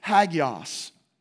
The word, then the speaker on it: hagios: When it says saints, it's actually a Greek term that is pronounced hagios.